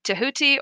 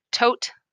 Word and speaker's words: Thoth: So I'm going to be saying Thoth. I like that more than Thoth or Thoth or Thoth, but obviously you're going to say it.